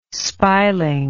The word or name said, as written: spiling